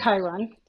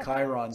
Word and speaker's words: Chiron: about is it Chiron or Chiron? I don't know how to pronounce that one. Chiron. – Yeah. Chiron.